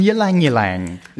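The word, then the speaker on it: ylang-ylang: That's a blend of bergamot, cardamom and ylang ylang.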